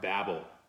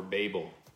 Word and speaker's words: Babel: And that's the Tower of Babel, or Babel, depending on how you have pronounced it in the past.